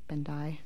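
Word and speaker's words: Bendii: I believe it is, actually, but it's B-E-N-D-I-I, pronounced Bendai.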